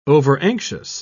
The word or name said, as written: overanxious